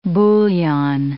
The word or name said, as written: bouillon